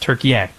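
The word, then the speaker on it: Türkiye: It's yeah, that it that is how it's spelled. But they now say the pronunciation is Turkey.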